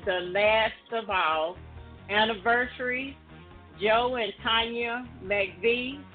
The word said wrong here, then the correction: McVeigh: And McVeigh. You're doing a good job, but it's McVeigh. – Morris and Denise Turner. Happy anniversary. – Happy anniversary. – Walter and Ofa, I-O-P-H-I-A.